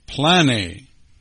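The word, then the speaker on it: plane (πλάνη): It's pronounced plane. Yes, it's the word we get planet from.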